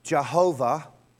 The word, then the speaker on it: Jehovah: It could be pronounced Jehovah and the same word, based on the way the um, vowel markings are placed, could also be um, Yahweh.